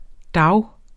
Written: DAGE

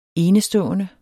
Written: enestående

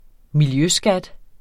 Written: miljøskat

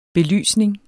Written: belysning

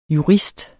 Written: jurist

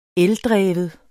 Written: ELDREVNE

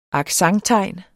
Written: accenttegn

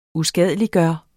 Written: USKADELIGGJORTE

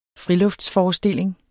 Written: friluftsforestilling

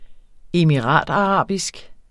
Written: emiratarabisk

